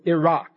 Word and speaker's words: Iraq: Today, we would call it the Middle East, Iraq, or Iraq, as I've been told I should pronounce it.